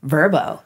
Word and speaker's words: Vrbo: Like, I think the company is pronounced Vrbo. And we were all like, what? Vrbo. Yeah. Like, it was like the commercial was just to tell us, like, no, this is a word.